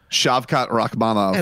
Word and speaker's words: Shavkat Rakhmonov: Shavkat Rakhmonov.